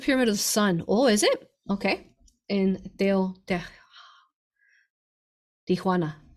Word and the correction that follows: Teotihuacan: I think I said that right. No, I don't think I said that right. Teotihuacan.